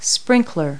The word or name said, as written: sprinkle